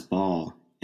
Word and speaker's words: Baal: you read a uh, uh as "ball," and that's right. Baal is a um, uh, one of Yahweh's chief adversaries in the Old Testament.